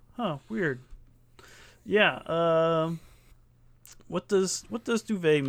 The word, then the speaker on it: duvet: Duvet is originally a French word, and it's retained its silent T in English, being pronounced duvet.